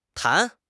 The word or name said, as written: tan2